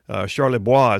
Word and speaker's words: Charlebois: The guy's literally, I mean, I read the article about the description of the man they arrested, Jerry Charlebois is how it's probably pronounced because he's in French-speaking Canada.